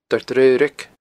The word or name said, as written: Doire Draoraig